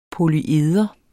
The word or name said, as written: polyeder